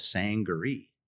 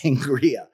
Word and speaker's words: sangaree: It's called a sangaree. And someone who was sitting at the bar said, no, it's pronounced sangria.